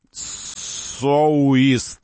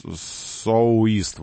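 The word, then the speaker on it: Soueast: Вот, произносить нужно соуист. Соу, как соуист, вот так.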